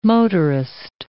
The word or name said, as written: motorist